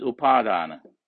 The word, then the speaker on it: Upadana: And the next word is Upadana. That's UPA long A, DA long A, DNA. UPA long A, DA long A, NA Upadana. That is Upa plus A plus Dana.